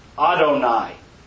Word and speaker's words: Adonai: But when in the Jewish Bible, if you see Yud Hey Vav Hey, it will be pronounced Adonai.